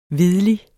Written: hvidlige

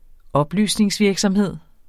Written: oplysningsvirksomhed

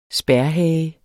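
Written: spærhage

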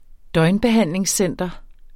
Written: døgnbehandlingscenter

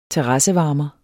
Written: terrassevarmer

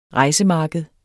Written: rejsemarked

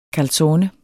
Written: calzone

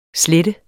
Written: slette

